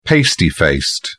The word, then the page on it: pasty faced: British English